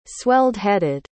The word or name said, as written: swelled-headed